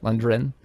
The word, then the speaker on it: Lundgren: Lundgren.